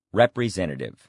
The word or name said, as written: representative